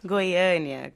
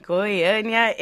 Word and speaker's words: Goiânia: Goiânia. – Goiânia. Where is that? – Goiânia.